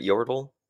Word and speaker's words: Yordle: Let's get into Heimerdinger, who is a highly intelligent and eccentric Yordle scientist. Am I saying that right, Yordle? – Yeah, I can explain what Yordles are a little bit after.